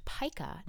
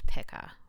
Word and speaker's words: pica: It turns out that the eating disorder Hunter has in Swallow is pronounced pica, not pica.